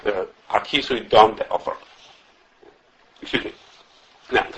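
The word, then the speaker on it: be'afar (בעפר): If the stress is on the ois groinius, then the preceding letter gets a kamatz, and if it's like in our case with a stress is on the end of the word be'afar, then the prefix of the shayrish gets a segel. Like in our case be'afar, be'afar, the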